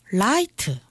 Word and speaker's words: light: 그리고 마지막 T. T는 강하게 터뜨리지 않고 짧게 닫아줬습니다. 그래서 light. – light. – 예, T 발음은 거의 T 거의 발음이 안 납니다.